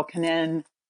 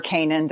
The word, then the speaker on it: Canin: But we also have a lot of different dog foods that you're probably familiar with, Pedigree or Royal Canin or Canin, depending on where you live, it's how you say it.